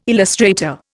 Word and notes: illustrator: Indian

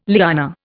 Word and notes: liana: Indian

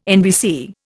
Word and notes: NBC: Indian

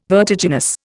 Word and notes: vertiginous: Indian

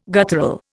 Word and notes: guttural: Indian

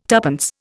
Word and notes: tuppence: Indian

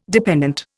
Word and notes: dependant: Indian

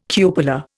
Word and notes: cupola: Indian

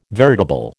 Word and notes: veritable: American English

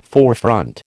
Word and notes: forefront: American English

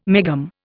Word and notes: megohm: Indian

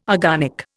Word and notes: agonic: Indian

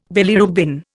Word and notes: bilirubin: Indian

